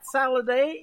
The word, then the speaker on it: Saladay: His name is Matt Saladay. It is salad with a long A,